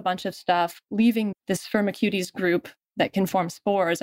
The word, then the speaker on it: Firmicutes: So this pill from Ceres Therapeutics is based on the idea of narrowing down the microbes to bacteria in this phylum called Firmicutes. I've heard it pronounced, I think, 3 different ways, but I prefer the cuties pronunciation. – I'll take cuties for this.